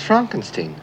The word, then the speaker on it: Frankenstein: Dr. Frankenstein. – Frankenstein. – You're putting me on. – No, it's pronounced Frankenstein.